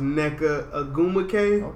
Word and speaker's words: Nneka Ogwumike: I think her name is pronounced Nneka Ogwumike.